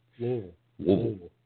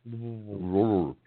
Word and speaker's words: Louisville: Anyway, this week we're packing up and getting ready to head to the Mid-America show at Louisville. Uh-huh. I think that's the correct pronunciation. – Louisville. – With a mouthful of mush. – Louisville. – Yeah.